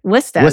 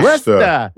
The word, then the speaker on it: Worcester: Worcester is how they say it. – Is that how you say it? – Yeah. – Worcester.